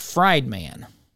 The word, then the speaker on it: Friedman: His last name is spelled Friedman. It kind of looks like fried man, but it's Milton Friedman.